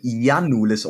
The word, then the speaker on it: Giannoulis: By the way, ich glaube, er wird Giannoulis ausgesprochen, weil er Grieche ist.